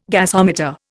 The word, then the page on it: gasometer: Indian